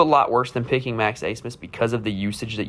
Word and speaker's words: Abmas: Kevin O'Banner, who ended up transferring to Texas Tech, and Max Asmus. It's spelled Abmas, but it's pronounced Asmus for whatever reason.